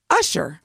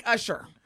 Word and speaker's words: Usher: And, you know, I love hearing some of us that are not black say, Usher. It's not Usher. It may be spelled like Usher. Usher, baby.